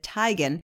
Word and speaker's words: Teigen: Model Chrissy Teigen, or she pronounces it Teigen, was chowing down on the chewy kid snack after husband John Legend's performance in D.C.